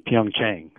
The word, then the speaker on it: Pyeongchang: Well, I think Pyeongchang.